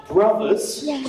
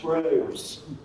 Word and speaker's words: Bros: We played this game, Super Smash Brothers Melee. I'm deliberately saying that wrong to annoy my children. Should be Super Smash Bros.